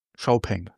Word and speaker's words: Xiaopeng: Nee, sag mir nur, wie spricht man es aus? – Xiaopeng. Okay. So hat es mir jedenfalls der Kollege von Xiaopeng erklärt. Gut. Hört sich auch schöner an als Xpeng.